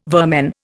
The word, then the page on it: vermin: Indian